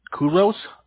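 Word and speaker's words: Querous: I work for Kuros.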